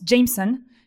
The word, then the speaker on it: JMSN: Donc, les initiales, je vous le dis si jamais vous voulez le retrouver, parce que ça se prononce Jameson. Mais ça s'écrit JMSN, donc c'est un petit génie américain.